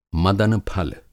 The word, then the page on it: मदन-फल: Hindi